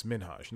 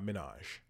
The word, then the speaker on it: Minhaj: It's Hasan Minhaj. He recently, in the last couple of years, took to interviews to talk about his last name and how it's Minhaj, not Minaj.